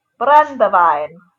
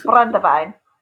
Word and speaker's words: brandewijn: But the name brandy, it comes from the Dutch term brandewijn. Did I say that correctly? Did it feel Dutch to you? – Yeah, I think that's right. – Yeah, brandewijn, which just means burnt wine, probably mostly referring to the heating part of the distillation process.